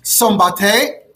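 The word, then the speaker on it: Szombathely: So this city of Steinmanger, it's known today by its Hungarian name, which is pronounced something like Sombate.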